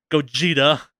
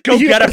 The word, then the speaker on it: Gogeta: Gogeta. I want to pronounce it Go. I pronounce it Gogeta. for like, so. for a fucking decade. Gogeta is so much cooler than Gogeta.